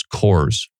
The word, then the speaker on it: Coors: I do believe it's Coors.